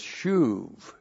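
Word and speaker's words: shuv (שוב): It's shuv. That's S-H long U-B. Shuv.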